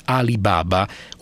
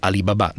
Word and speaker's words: Alibaba: L'altro sito di ricerca online cinese è Alibaba, o Alibaba, dipende dove mettete l'accento, che è partecipato tra gli altri da Yahoo.